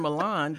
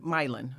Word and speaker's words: Milan: And you're thinking you're in Milan, but you're in Milan.